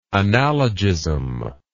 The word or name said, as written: analogism